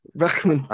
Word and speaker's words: Bregman: Bregman.